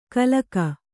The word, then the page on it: ಕಲಕ: kalaka